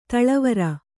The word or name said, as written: ಧಾಳಿವರಿ